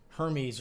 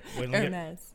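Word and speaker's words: Hermès: I start calling that one that starts with an H, like Hermes or something. But it's Hermes.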